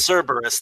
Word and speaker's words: Cerberus: It's Cerberus, the three-headed dragon-tailed dog that was the guardian of the gates of the underworld.